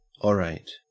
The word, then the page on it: orate: Australian English